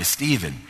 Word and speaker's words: Stephen: And I said, his name shall be Stephen, just like it's spelled, S-T-E-P-H-E-N.